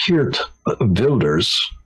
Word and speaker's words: Geert Wilders: His name is pronounced differently than it appears on the page. It's Geert Wilders, mm-hmm. In the Dutch language.